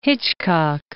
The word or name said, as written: Hitchcock